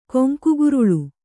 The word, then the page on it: ಕೊಂಕುಗೊರಲ್: koŋkugoral